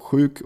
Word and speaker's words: sjuk: Also Chök oder krank ist ja S-J-U-K.